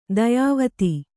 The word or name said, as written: ದಾಯವಾದಿ